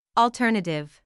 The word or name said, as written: alternative